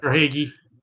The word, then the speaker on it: Verhaeghe: But some of the key players for Florida, Carter Verhaeghe. I'm probably saying that wrong. Sorry about that. – Verhaeghe.